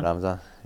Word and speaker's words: Ramzan: Ramzan.